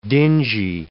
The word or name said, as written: dingy